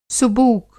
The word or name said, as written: सुबूक